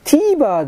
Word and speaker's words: TVer: これに関しては、あのー、僕使ったことないから全く知らないんだけど、テレビに ER と書いて TVer でないかなと思うんですが、 TVer というところで、 1 週間ぐらいは多分、よくあるじゃん。